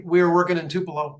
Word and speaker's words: Tupelo: We're not like a, you know, it's, so let's say we were, and I'm just using Tulupo because, and I, did I pronounce the name right? – Tupelo. – Tupelo. I knew I had it off a little.